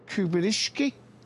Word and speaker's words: Kubierschky: and it's Ruth Kubinski. Don't worry if you have a hard time pronouncing it. Everyone messes up. In M-A-U-S.